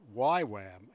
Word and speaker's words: YWAM: It turns out that David Cunningham is the son of the two founders of a worldwide cult whose name is usually pronounced YWAM,